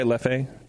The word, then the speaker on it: Leffe: Am I saying that right? Leffe? L-E-F-F-E? – We'll go with that.